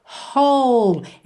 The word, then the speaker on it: whole: Whole. Essa palavra inteira, inteiro, W-H-O-L-E, tem a pronúncia whole. Whole. Ou seja, na verdade, você ignora esse W inicial, ok?